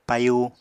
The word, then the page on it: payu: Apatani